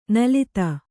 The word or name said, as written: ನುಲಿತ